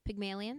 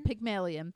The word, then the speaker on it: Pygmalion: Pygmalion. – Pygmalion.